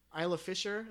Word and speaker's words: Isla Fisher: Isla Fisher. Is that how you say it? – Yeah.